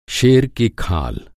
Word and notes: खाल: کھال

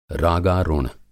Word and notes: रागारुण: Hindi

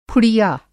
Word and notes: फुड़िया: Hindi

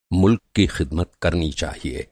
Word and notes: ख़िदमत: Hindi

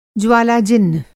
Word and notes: ज्वालाजिह्व: Hindi

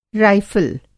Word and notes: राइफ़ल: رائفل

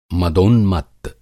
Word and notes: मदोन्मत्त: Hindi

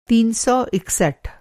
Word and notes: तीन सौ इकसठ: Hindi

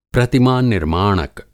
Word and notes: प्रतिमा-निर्माणक: Hindi

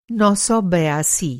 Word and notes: नौ सौ बयासी: نو سو بیاسی

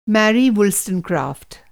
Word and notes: मेरी वुलस्टोनक्राफ़्ट: Hindi

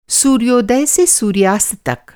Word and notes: सूर्योदय: masculine noun

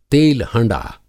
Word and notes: तेल हँड़ा: Hindi

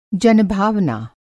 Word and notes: जनभावना: Hindi

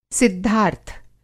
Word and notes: सिद्धार्थ: Hindi

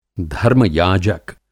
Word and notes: धर्म-याजक: Hindi

